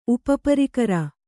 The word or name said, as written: ಉಪಪರಿಕರ